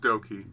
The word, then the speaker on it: Douki: Yeah, it's pronounced Doki. – Doki. – D-O-U-K-I.